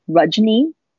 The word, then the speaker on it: Rajni: So Rajni rhymes with fudgeni. If you imagine some fudge on your knee, you got my name.